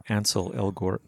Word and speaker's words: Ansel Elgort: And we should say that Baby Driver is played by Ansel Elgort, if I'm pronouncing that correctly.